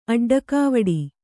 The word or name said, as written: ಅಡ್ಡಕಾವಡಿ